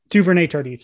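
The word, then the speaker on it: Duvernay-Tardif: Uh, they may actually go with Louverde Tardif. I completely butchered Duvernay Tardif. – Duvernay Tardif, sorry.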